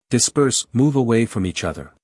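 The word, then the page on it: disperse: uk /dɪˈspɜːs/; en-us /dɪˈspɜːrs/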